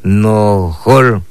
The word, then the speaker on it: nohol: Sur lo pronunciamos de esta manera. Nohol. Así como suena. No hay que corregir, nohol es sur en lengua maya.